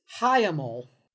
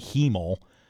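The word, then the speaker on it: hiemal: It's H-I-E-M-A-L. I thought it was going to be hemel, and I don't like that either.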